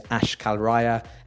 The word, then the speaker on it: Ash Kalraiya: My full name is Ash Kalraya, A-S-H-K-A-L-R-A-I-Y-A.